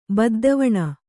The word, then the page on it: ಬದ್ದೆವೆಣ್: baddeveṇ